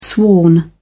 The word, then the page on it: sworn: British English